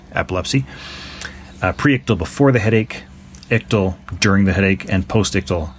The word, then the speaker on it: ictal: Anyway, the authors of this particular study are using the terms ICTL, or Ictal, I guess some people pronounce it, but we're going to go with Ictal today.